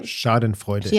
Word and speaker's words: Schadenfreude: Schadenfreude.